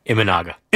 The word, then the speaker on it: Imanaga: Talking Yankees, talking Mets, both of them, big series, Mets later today, 2:20, Quintana versus Imanaga. Am I saying that right? I always get that name wrong. – Imanaga.